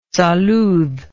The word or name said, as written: salud